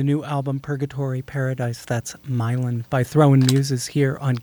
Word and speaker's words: Milan: You can call it Milan if you want. Lots of Italian people do. But it's... It was written in my neighborhood in New Orleans, which is pronounced Milan, spelled Milan.